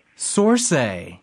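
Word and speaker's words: Saoirse: How do you pronounce her name? – Sorsay.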